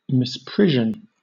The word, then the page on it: misprision: British English